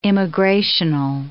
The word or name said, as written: immigrational